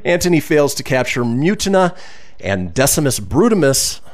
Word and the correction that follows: Brutus: Brut, Brutus. God, I did butcher it. Decimus Brutus.